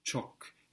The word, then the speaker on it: çok: Çok. It's spelt C with a little tail on the C, which gives us the equivalent to CH in English.